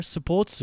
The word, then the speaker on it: スポーツ: Nanika sports, now have the long O there, sports So this is what kind of sports?